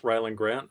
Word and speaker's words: Rylend Grant: I am at Rylan Grant on all forms of social media. That is R-Y-L-E-N-D-G-R-A-N-T.